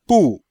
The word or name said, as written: bu4